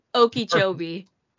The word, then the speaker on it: Okeechobee: Florida man was allegedly planned mass shootings and stabbing sprees at, I don't know if I'm pronouncing this correctly, Okeechobee High School. – Okeechobee. – Okeechobee? Oh, okay. – Yeah. Good job.